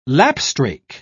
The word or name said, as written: lapstrake